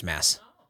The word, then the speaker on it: Mads Mikkelsen: Yeah, Michael looked up Mads Mikkelsen and showed me that it's pronounced Mass.